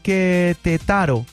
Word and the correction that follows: Querétaro: Querétaro. – Querétaro. – ¿Cómo se dice? – Querétaro, chavito.